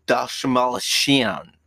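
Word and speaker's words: Dastmalchian: Oh my God, he's like, can't you pronounce my name? David Dashmalshian. That's how you say it.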